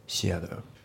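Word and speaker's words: theater: あと発音ですけど、シアターの T がね、あの D で濁ってますね。まあ、フラッピングだよね。シアター、ね、シアターじゃなくてシアターですね。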